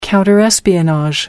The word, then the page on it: counter espionage: American English